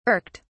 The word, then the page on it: irked: British English